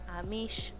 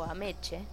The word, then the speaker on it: Ameche: Ese año ganó Don Amish o Ameche. Yo digo Amish para hacerme la cool, pero se escribe Ameche por Cocoon y mejor actriz de reparto ganó Angélica Houston por El honor de los Prisi.